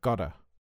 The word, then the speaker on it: gotta: The most common way to speak about something you have to do is to say, I've got to, or I've gotta. – It's important to focus on the pronunciation here. Have a listen.